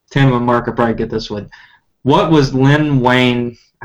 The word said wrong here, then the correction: Wein: Len Wein, I believe. – Len Wayne, sorry. What was Len Wein doing when he thought of the character of Swamp Thing?